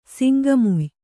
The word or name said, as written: ಸಿಂಗಮುಯ್